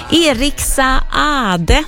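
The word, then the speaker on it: Eric Saade: Eriksa Ade, så har jag ju lärt mig att man ska uttala hans namn. Han stavar ju S-A-A-D-E och då ska man tydligen uttala båda och en också, så då gör jag det.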